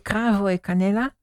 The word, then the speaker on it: Cravo e Canela: En Cravo e Canela. Spreek je zo goed uit? – Ja.